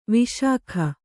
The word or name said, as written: ವಿಶಾಖ